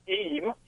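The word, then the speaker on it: Eyam: You're mispronouncing it. The correct pronunciation is Eam.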